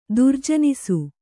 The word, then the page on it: ದುರ್ಜನಿಸು: kannada